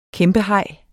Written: kæmpehaj

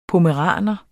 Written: pommeraner

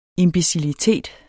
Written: IMBECILITET

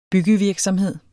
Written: byggevirksomhed

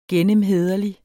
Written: gennemhæderlig

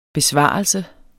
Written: BESVARELSEN

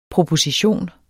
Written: proposition

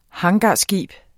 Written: hangarskib

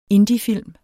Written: indiefilm

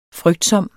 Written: frygtsom

